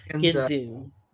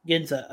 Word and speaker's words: Ginza: Like, I mean, the attack on, you know, is it, sorry if I mispronounce, is it Ginza or Ginzu? Ginza. Okay. Excuse me.